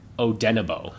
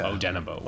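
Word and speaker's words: Odenigbo: Someone who apparently has been really good and was really good yesterday is the Afedi Odenebo, which is how you say his last name. – Okay. – Odenebo.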